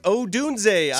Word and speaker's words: Odunze: Is that how you say it? – It's Odunze.